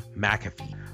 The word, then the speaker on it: McAfee: But the grand scheme of things, Pat McAfee, which is how you pronounce his name, McAfee. – you know, like the software.